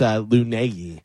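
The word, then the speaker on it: Laneige: It's L-A-N-E-I-G-E, but they say it phonetically is Lunegi.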